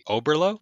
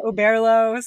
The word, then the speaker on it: Oberlo: So the appropriate way is to say Oberlo. – That's how I say it. But honestly, the founders say Oberlo.